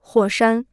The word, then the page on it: 火山: huǒ shān